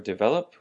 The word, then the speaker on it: develop: So for the word develop, we have the emphasis on the vel, develop, and that's a verb that means 使成长 or 使发达.